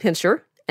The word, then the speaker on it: Pinscher: The Doberman Pinscher, and that's Pinscher with an S-C-H, not Pinscher, gets the Doberman part of its name from a German dog breeder named Ludwig Dobermann.